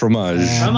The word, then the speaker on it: fromage: I'm from Montreal, Quebec, and it's pronounced Maison de Fromage.